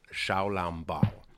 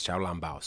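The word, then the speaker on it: xiaolongbao: So now everybody's chasing these things called Shaolambao. That's the name. That's how you say it in Chinese. Shaolambao, soup dumplings.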